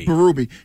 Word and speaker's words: Berube: How do you say his name? – Berube.